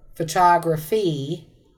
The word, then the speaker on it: photograph: Photograph. Photograph. I'm stressing the first syllable, right? – Right, A three-syllable word.